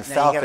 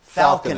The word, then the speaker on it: Falconer: pronounced his book, Falconer, Falconer as Faulkner.